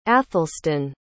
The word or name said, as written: athelston